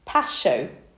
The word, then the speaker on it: pascho: The most common one, I'm going to try and pronounce this, is pascho. It's P-A-S-C-H-O, little line over the O.